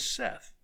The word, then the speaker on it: saith: And he saith unto them, by that way, that word, S-A-I-T-H, it is not pronounced saith. The correct English pronunciation is Seth, S-E-T-H.